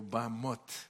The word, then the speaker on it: bamot: pero sin embargo cuando se habla en hebreo, bamot, bamot. Y tal vez nosotros si nos dicen escriba bamot, ponemos B-A-M-O-T, e ¿verdad?